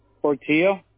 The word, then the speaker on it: Portillo: Portillo. – Can you spell that? – P-O-R-T-I-L-L-O.